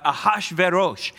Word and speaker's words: Ahasuerus: So I was close, not completely on it, but Ahasuerus. I get that from a Jewish rabbi. I'm going to take that as gold on the pronunciation of that hard name.